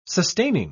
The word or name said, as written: sustaining